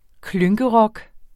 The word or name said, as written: klynkerock